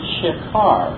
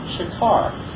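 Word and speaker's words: shikar (שֵׁכָר): The Hebrew word used for strong drink in Proverbs 20, verse 1, is the word shikar. Again, the English letters spelled phonetically would be S-H-E-K-A-R, shikar.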